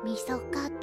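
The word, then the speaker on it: 三十日: 30 日と書いてみそかと読むの。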